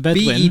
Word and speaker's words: Bedouin: So I'm going to stab in the dark. – I believe. – Bedouin? – The Bedouin. – B-E-D-O-U-I-N. – Yeah, Bedouin people. – The Bedouin people, there we go.